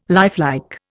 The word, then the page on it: lifelike: Indian